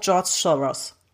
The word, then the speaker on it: George Soros: Ja, auch George Soros.